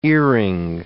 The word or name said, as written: earing